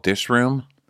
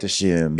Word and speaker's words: Dishoom: Wasn't it eventually called Dish Room? Or was it called Dishoom? – She's like, we don't pronounce the R in England. We just call it Dishoom.